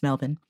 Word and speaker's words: Melbourne: For example, Daniel from Melbourne, Australia, a friend of mine taught me that it's pronounced Melbourne.